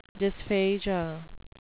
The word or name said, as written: dysphagia